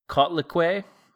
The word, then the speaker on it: Coatlicue: It's an Aztec goddess named Cotlicue, C-O-A-T-L-I-C-U-E.